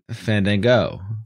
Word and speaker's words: Fandango: Fandango? – Fandango.